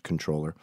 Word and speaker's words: comptroller: The CEO, the chief financial officer, the controller, yes, comptroller is pronounced controller.